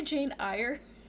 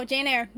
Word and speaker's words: Eyre: Did you say Jane Eyre? – Is that not how it's said? – No. It's Jane Eyre. – Oh, Jane Eyre.